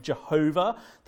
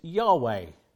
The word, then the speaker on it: Yahweh: And so what did happen in history, and some of the older Bible translations will write Jehovah. That's a mispronunciation. It's an error. It shouldn't be at all pronounced like that. The closest we think is the pronunciation is Yahweh.